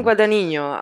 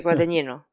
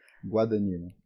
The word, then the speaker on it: Guadagnino: Guedanino? E, se tako izgovara. – Aha, ja uvek hoću kažem Guadaninjo, a u stvari je Guadanino. Guadagnino, ok, predivno.